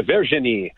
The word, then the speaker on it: Virginie: Virginie? – Yeah, Virginie.